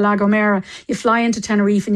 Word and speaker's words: La Gomera: Yeah, La Gomera.